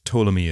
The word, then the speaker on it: Ptolemy: Now, before we get started, Ptolemy is spelt with a silent P at the start of the name, mm-hmm.